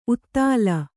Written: ಊದಲು

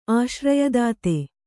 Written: ಆಶ್ರಯದಾತೆ

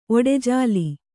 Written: ಒಡೆಜಾಲಿ

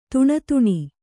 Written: ದೀನತನ

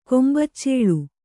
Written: ಕೊಂಬಚ್ಚೇಳು

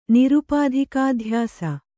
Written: ನಿರುಪಾಧಿಕಾಧ್ಯಾಸ

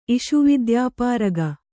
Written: ಇಷುವಿದ್ಯಾಪಾರಗ

